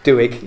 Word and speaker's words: Doig: And he's got an equally passionate assistant manager we heard yesterday at Chris Doig, which you can't pronounce. – Doig, yeah.